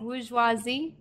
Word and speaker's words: bourgeoisie: I oh always thought that it was bourgeois. – Yeah, bourgeoisie. Yeah. Well, it's bourgeoisie.